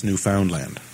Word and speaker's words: Newfoundland: I think, really, it's Newfoundland, you know. – Somebody once told me it rhymes with understand. Newfoundland, understand.